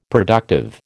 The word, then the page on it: productive: American English